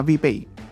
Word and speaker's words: VPay: Se llama VPay. – VPay. – Sí, que es la empresa a la que está llevando a cabo el servicio. Se escribe, es VPay, V y latina, P-A-Y.